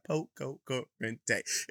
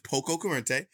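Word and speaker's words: poco curante: How many syllables is that? Poco corriente. It's 5 syllables, ladies and gentlemen. It's poco corriente, and it's an adjective.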